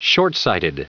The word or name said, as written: shortsighted